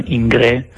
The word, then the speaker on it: Ingres: Poi appunto ho guardato questi dipinti, in particolare quello di, non so come si pronuncia, si scrive Ingres, ma penso sia Ingré, che è francese, in cui addirittura Zeus si appoggia a una nuvola come se fosse parte del suo trono, no?